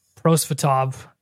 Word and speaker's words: Prosvetov: Uh, yeah, Ivan Prosvetov. Did I say that right? – Sure. We'll that. That sounded good.